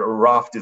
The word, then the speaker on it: Aravt: We call it Aravt, but Aravt is the proper way to pronounce it in Mongolian.